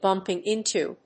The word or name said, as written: bumping into